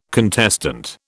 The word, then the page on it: contestant: British English